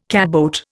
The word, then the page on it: catboat: Indian